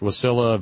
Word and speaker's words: Wasilla: And she says it's pronounced Wasilla, a very nice little town when she lived there.